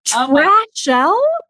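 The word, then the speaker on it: Trashelle: It's even spelled like that. Like, trash, E-L-L-E. It's not even, like, with a C-H. It's literal trash with an L.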